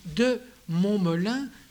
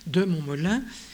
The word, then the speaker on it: de Montmolin: Alors, deux noms ressortent en France, c'est de Montmolin, je ne les mets pas au tableau parce que c'est comme ça se prononce, Mont, comme un mont, M-O-N-T, Molin, M-O-L-I-N, de Montmolin.